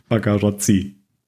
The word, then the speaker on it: Bagarozzi: Und hier hat er auch Regie geführt und auch Drehbuch geschrieben, aber noch mit Anthony Bagarozzi dazu. Ich habe den jetzt einfach mal deutsch-italienisch ausgesprochen. – Der Anthony. – Bagarozzi. – Bagarozzi.